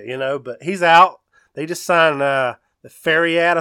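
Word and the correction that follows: Kenneth Faried: I think is how you say it. How do you say it? – I don't know. – Dude, how do you say that, Biggs? – Kenneth Fareed. – Fareed.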